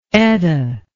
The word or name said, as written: Etta